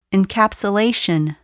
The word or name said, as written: encapsulation